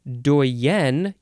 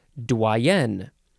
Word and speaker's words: doyen: Doyen. You emphasize the second syllable here. Doyen or dwayen.